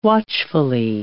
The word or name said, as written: watchfully